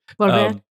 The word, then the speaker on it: Volver: Volver.